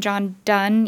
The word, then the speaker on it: Donne: And there's this poem by, I think you pronounce his name John Donne, even though it's D-O-N-N-E.